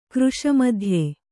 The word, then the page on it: ಕೃಶಮಧ್ಯೆ: kannada